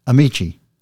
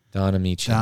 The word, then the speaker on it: Ameche: A-M-E-C-H-E. – Amici. – Don Amici.